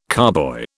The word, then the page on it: carboy: British English